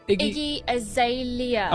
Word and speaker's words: Iggy Azalea: Iggy Azalea.